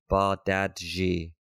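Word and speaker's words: Bardghji: The accurate way to say his name is Rooney Bardadji. Bardadji. He said, according to the man himself.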